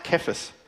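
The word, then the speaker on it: Cephas: Jesus was the one that actually gave Simon the name Peter, which is Aramaic for rock, and it's pronounced Kephas.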